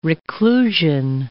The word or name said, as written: reclusion